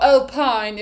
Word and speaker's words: Alpine: There is Alpine. Or Alpine if you're American. Or Alpine if you're trying to figure out how to say the name. It's a French F1 team.